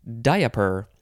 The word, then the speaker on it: diaper: The first thing that I can tell you about it is that you can also pronounce it diaper, because that's how it's spelled. You can make it three syllables.